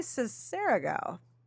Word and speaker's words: Sessarego: I have been saying your last name wrong. Sessarego? – Yeah, no one can say my last name. – Sessarego. Is that Italian? – Yes. So it should be Sessarego. And when I was in college, I decided I would tell everyone that that was how to say it.